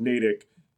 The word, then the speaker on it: Natick: After the, you keep seeing Natick. – Natick. Yeah. And I was like, over and over again. I took the, and I mean, like, one nice thing is his depiction of Natick is so absolutely spot on.